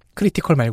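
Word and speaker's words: Kree: 크리티컬 말고요. 음. – k r e 이었나 크리. 영화에서는 그렇게 읽지 않아.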